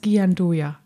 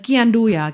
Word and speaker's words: Gianduja: Das dunkle Nougat, ich bin mir nie sicher, ob es wurde in meiner Gegenwart schon als Jean Dujou und als Gianduja ausgesprochen. – Ich kenne das als Gyandoja. – Als Gyandoja, genau das.